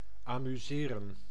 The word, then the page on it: entertain: American English; [ˌɛn.tɜː.ˈteɪn]